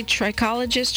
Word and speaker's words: trichologist: Is that how you say trichologist? Trichologist, how do you say it? – Trichologist.